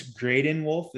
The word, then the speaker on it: Graydon Wolf: Graydon, I'm assuming it's Graydon Wolf. Is that how you pronounce it? – Yeah, another rhymer.